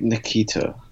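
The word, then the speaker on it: Ntilikina: Frank Nikita. – So the L is silent.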